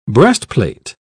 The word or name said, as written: breastplate